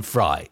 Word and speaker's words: Frey: There's also an appearance from Glenn Frey, or is it Glenn Fry? It's spelled one way, pronounced the other.